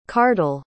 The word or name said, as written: kardal